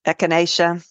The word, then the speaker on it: echinacea: I can't pronounce the echinacea.